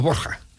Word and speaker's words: Borja: No, los Borgia son los Borja. Lo que pasa es que Borja en valenciano es Borgia. Y los italianos, para poder pronunciar Borgia, tenían que escribir Borgia. Y al final ha quedado, nosotros decimos, en vez de Borja, los Borgia.